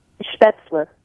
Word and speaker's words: Spätzle: So what is Spätzle, and did I mispronounce that? – Spätzle. It's they're a Bavarian egg noodle.